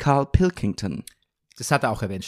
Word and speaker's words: Pilkington: Die sagt Pilkington oder dann, dann er, sie haben es gerade dreimal verschieden und dreimal falsch gesagt. Also er, er, geht auf das King, Pilkington.